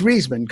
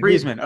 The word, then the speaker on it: Reisman: What I like to say is, and I don't apologize if I spelled his name, if I pronounce his, I'm sorry, pronounce his name incorrectly. Yeah, it's Reisman. Go ahead. – Reisman.